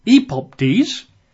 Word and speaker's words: epoptes: Or the other Greek word would be epoptes, E-P-O-P, T-E-S, E-P-O-P, T-E-S, and he references 2 Peter 1:16.